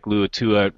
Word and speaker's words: Luatua: I think a guy like, how do you say his name? Luatua? – Hey, Luatua. – Luatua? – I believe that's how you say it. – Yeah, that's right. I'll get that right.